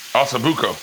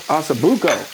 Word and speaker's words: Ossobuco: Hasta de Boco. How you say it again? – Ossobuco. – Ossobuco.